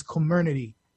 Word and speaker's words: Comirnaty: I'm going on record as saying it. The pronunciation is Comirnaty.